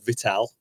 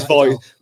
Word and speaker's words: Vital: We actually had a conversation about this just before the podcast, and I pronounced it wrong. I called it Vital. But it's Vital.